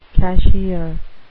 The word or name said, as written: cashier